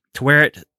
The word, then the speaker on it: Tawaret: Tawaret says they have to go back to the Asylum and reconstruct their life.